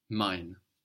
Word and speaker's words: mein: Mein. – Mein. – Mein. So mein means my. So ein is spelled E-I-N. We know this E-I gives us the I sound. And mine is spelled M-E-I-N.